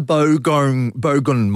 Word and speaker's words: Bogong: Oh, this is the bogon moth, sorry. This is a type of breed. – They're bogon moths. – Oh, it's spelled B-O-G-O-N-G.